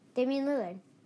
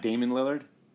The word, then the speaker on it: Damian Lillard: Damian Lillard. – It's not Damon Lillard? – Damon Lillard, no. Damian.